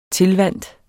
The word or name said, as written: tilvant